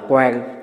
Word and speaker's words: quán: Như thầy vừa nói hồi nãy đó, cũng là chữ Hán đó có thể đọc là quan, tức là nhìn thấy. Quán á, tức là nhìn bằng tâm. Còn cái quan đó là nhìn bằng con mắt.